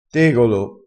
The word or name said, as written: tegolo